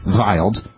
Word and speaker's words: Wild: Comets like Wild 2, named for astronomer Paul Wild, actually pronounced Wild,